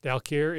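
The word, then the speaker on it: Dal Quor: During what activity do mortals usually encounter the plane of existence called Dalquor? And I may have said that wrong. It's D-A-L space Q-U-O-R. – Dreaming. Dalquor is the realm of dreams.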